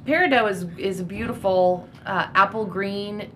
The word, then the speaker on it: peridot: August's birthstone is peridot. It's spelled P-E-R-I-D-O-T, but the T is silent.